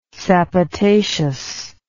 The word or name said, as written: sapotaceous